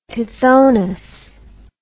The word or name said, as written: Tithonus